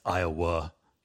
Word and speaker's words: Iowa: So the other thing that this reminded me of is last week, apparently I was saying Iowa wrong. I say it like Ottawa. Apparently it's Iowa, not like UH, not an A.